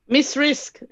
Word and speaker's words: Mz Rizk: Hey, tell me, it's pronounced Ms. Rizk? – Miss Rizk, as if it's S's, you see.